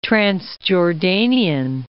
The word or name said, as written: Transjordanian